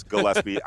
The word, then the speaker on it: Gillespie: People call him sometimes Gillespie, but it's Gillespie.